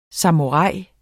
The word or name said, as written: samurai